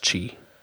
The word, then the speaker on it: Qi: It's pronounced Qi. Qi is pronounced Qi.